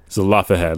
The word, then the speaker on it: Zelophehad: Yeah, Zelophehad. Is that how you pronounce it? Yeah. – Zelophehad.